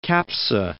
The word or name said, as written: Capsa